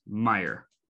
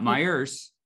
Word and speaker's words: Meijer: And let's wait, before we go into get a little deeper into Meijer, let's clarify for the out-of-towners, it is Meijer. It's not Meijer's.